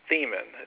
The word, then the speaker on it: Thiemann: And my last name's actually pronounced Thiemann. It's German.